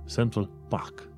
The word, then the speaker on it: Central Park: Și atunci un britanic nu ar spune Central Park, ar spune Central Park. PAC. Central PAC.